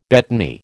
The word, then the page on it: betony: American English